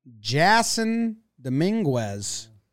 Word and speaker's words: Jasson Dominguez: Jasson Dominguez.